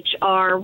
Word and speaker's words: Chrystal: Just remember that my first name is spelled CHRYSTAL, Hammer, just like the tool, and Crystal, just like the stripper.